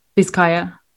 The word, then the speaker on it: Bizkaia: Bazinga Durango, I believe, is the team that got, I think that's how you say it. – Bizkaia. – Bizkaia, sorry.